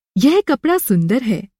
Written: कप

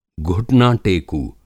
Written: घुटनाटेकू

